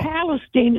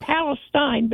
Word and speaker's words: Palestine: No, Palestine. It's spelled like Palestine, but Palestine.